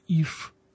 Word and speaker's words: иш: То есть «муж» – это слово произносилось как «иш». А «жена» – это слово произносилось как «иша», если дословно перевести, муж – это муж, а жена – мужнина.